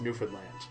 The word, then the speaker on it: Newfoundland: How it's spelled, but anyone who knows anything about Newfoundland culture knows that it's pronounced Newfoundland.